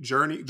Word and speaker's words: Jurnee: Is that how you say it? Journey. Girl. – Journey. – Sister. Yeah, it's Journey.